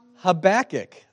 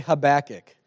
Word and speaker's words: Habakkuk: Let's let's learn how to say his name. Habakkuk. That's right. Everybody say Habakkuk.